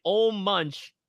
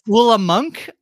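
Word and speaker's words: Ole Munch: Ol' Munch making biscuits together? – I believe he gives a correct pronunciation for his name, which is Ulla Munk. He's Ulla not Ol' Munch. He's Ulla Munk.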